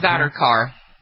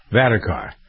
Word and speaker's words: Varadkar: Is that how you pronounce his name? – Varadkar. – Varadkar.